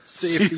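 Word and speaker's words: Syfy: Yeah, CIFI.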